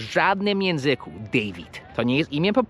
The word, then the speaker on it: David: David. I widzisz, tam jest litera I. To się wymawia D-W-I-D. David. To nie jest David.